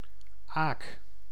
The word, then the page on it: aak: [aːk]